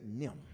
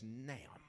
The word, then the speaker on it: 'nem: Nim is a southern colloquialism that means and them, but we don't say all of that. There's too many syllables. So we just say Nim. Come on, practice, church. Nim. Come on, digital. Nim. Let me spell it for you. Apostrophe N-E-M. If you're real deep south, it's not Nim, it's Nam, which is a combination of Nan and Nim together. You just roll it all in one.